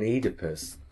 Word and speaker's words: Oedipus: And of course, also when you've got O before E, as in Oedipus, that seems to have the E sound rather than the O sound as well.